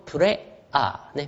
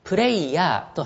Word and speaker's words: prayer: p-r-a-y-e-r です。 p-r-a-y-e-r、これ発音プレアーね、プレアー、これが、プレアー、これがノリトでございます。浸透プレアー、浸透プレイング、これノリトです。はい、プレイヤーと発音すると祈る人っていう人の意味になっちゃいます。